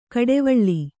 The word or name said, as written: ಘಟ್ಟಿವಳ